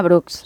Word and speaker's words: Brooks: Bueno, pues la marca se llama Brooks.